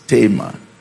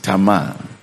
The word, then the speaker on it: Tamar: Now the English pronunciation for this one is Tamar, but the Hebrew is Tamar. So I will stay with the Hebrew.